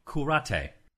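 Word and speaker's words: Curate: It's spelled C-U-R-A-T, and they spelled out C-O-O-R-A-T-A-Y, Kurate.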